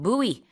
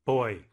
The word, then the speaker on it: buoy: 네, 그 안쪽에서 안전하게 수영을 하셔야 되는데 부표 영어로 buoy. 또 다른 발음이 있어요. buoy. – Boy. – 이건 그래서 헷갈릴 수 있어요. 그냥 남자 아이랑 이제 발음이 같으니까. 근데 boy라고 하기도 하고 buoy라고 하기도 하는데 스펠링은 buoy고요.